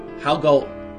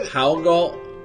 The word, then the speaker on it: Haugaard: H-A-U-G-A-A-R-D, that's as Danish as it gets. It starts with H-A-U, pronounced how, but the G-A-A-R-D... contains nearly none of the sounds that are in those letters, except for maybe the G. It's a hard G. The new Stoke goalkeeper is former Midtjylland standout, Jakob Haugal. – Wait a minute. – Is that right? – Is that there's nothing else at the end? No, say it again. – Haugal. Gulp. It's the soft D in Danish.